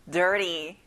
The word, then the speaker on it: dirty: 친구들, T 발음은 부드럽게 내주세요. dirty.